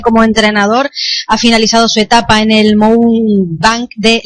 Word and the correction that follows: Mohun Bagan: Bueno, sí, Mohun Bagan, sí.